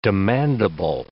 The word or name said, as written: demandable